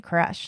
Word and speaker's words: Koresh: This actually, interestingly, goes back to the conversation we were having about David Koresh, too. Because cult leaders tend to be... – Very, very charismatic. I've only seen it in writing. – Yeah. That's exactly how you say it. Good decoding.